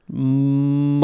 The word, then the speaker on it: molybdenum: It's spelled so crazily that I have a hard time pronouncing it. – Oh. – So I'm in a molybdenum loop. Molybdenum is what it is. – Molybdenum.